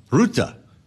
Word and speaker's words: Rutte: Not everyone seems to know how to pronounce that. Don't worry. Try to roll the R if you want. Rutte.